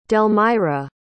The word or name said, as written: delmira